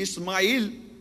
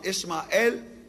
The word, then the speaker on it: Ishmael: According to the Bible, and of course it's the Jews and Christians' faith, in Genesis 16 and verse 12, he, Ismail, or Ishmael, that is the biblical pronunciation in English, will be a wild man.